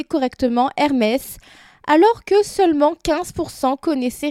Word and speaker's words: Hermès: Et sur le t-shirt, c'est écrit Hermès, R, donc A-I-R, et Mess, M-E-S-S.